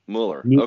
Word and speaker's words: Møller: Müller. Okay. Go ahead. – As if it was M-E-U-L-L-O-R.